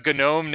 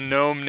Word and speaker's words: gnome: It's a gnome named Gnorm, and but it looks like it's a gnome named Norm. But in the movie, you learn that both the word gnome and the name Norm are don't have silent G's.